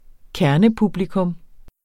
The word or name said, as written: kernepublikum